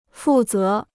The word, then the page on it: 负责: fù zé